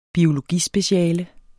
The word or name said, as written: biologispeciale